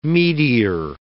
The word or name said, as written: meteor